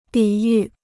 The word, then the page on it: 抵御: dǐ yù